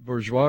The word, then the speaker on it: Bourgois: By the way, although he pronounced it Bourgeois, his name was spelled B-O-U-R-G-O-I-S.